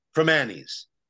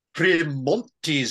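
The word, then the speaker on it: Primanti's: For the love of all that is holy, stop pronouncing the T in Premanis. It's pronounced Premanis. This Premontis stuff makes it sound like a classy establishment, and the best ones are the exact opposite.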